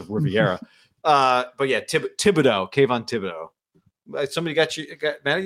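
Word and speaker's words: Thibodeaux: Thibodeau.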